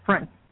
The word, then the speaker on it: Frank: Frank. Yep. – Frank, as in Frankfurter. Am I right? – Yes, in Frankfurt, without the fur.